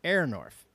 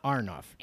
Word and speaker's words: Aronoff: The first syllable of the Performing Arts Center in Cincinnati, as we call it, Cincinnati, is pronounced air, not R. So it's Aronoff. Aronoff, not Arnoff. – Aronoff. – Now, what I want to say is, well, then they should spell it with an I. – With an A-I? – Before the R. – Like air? – Right, like Aronoff, A-I-R-N-O-F, not spell it A-R-N.